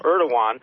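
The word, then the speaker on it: Erdoğan: Also, an obscure point is that Turkey President Erdogan, spelled with an G, it doesn't match with the way we understand it, but I understand it's pronounced Erdogan, has appealed for 57 Muslim nations to siege Israel.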